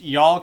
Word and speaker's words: You'll: So Viper is most famous for his breakout single is pronounced Y'all Cowards Don't Even Smoke Crack, but y'all is spelled YOU'LL.